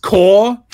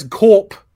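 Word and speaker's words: Corps: Okay, when you start up the game, she says corp. Right? It's pronounced core, right? I don't know. Like, it's like, when she says corp, it's like, okay, so now you're saying we're just following a game to do with the Umbrella Corporation.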